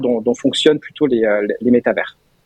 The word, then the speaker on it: métavers: Voilà pourquoi on prononce pas le S à la fin. Voilà, je fais mon intéressant. En français. Hein. en français, oui, bien évidemment. – Il faut dire métaverse.